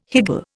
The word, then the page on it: higgle: Indian